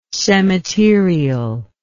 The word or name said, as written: cemeterial